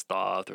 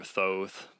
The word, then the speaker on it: Thoth: It's commonly pronounced as Thoth or Thoth, but, you know, just whatever works.